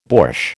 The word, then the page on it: borsch: American English